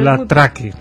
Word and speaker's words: La Traque: Es una película titulada, bueno, se está rodando, La Track. Uh-huh. ¿Se pronuncia así? – Déjame que vea cómo se escribe. Pero La Traque, la caza.